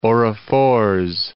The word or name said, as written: Orrefors